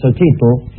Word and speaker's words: Xochitl: Yo, por ejemplo, para decir flor, digo Xochitl, claro. – Dándole la L casi como una... como Julio Sosa.